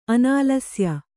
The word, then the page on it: ಅನಾಲಸ್ಯ: kannada